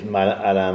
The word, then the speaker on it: Alain: My name is Alain Mas, and nobody pronounced it that way, so it doesn't matter anymore.